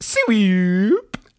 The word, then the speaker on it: Swewp: S-W-E-W-P. It's pretty much phonetically. – So it's pronounced sweep.